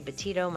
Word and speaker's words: Betito: I'm easy to find, uh, Laurie Betito. My last name is spelled B-E-T-I-T-O.